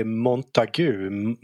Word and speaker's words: Montague: Vad uttalar han det? Han uttalar det Montague.